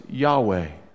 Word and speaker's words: Yahweh: You can't pronounce four consonants. I mean, how do you say that? You can't. It's four consonants. So, over time, what took place is they added some vowels to it, and it was Yahweh.